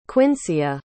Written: quincia

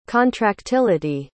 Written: contractility

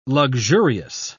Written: luxurious

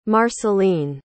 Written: marceline

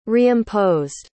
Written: reimposed

